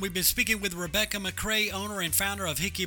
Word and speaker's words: McCrea: The Facebook page is just Rebecca McCray, and the last name is spelled MCCREA.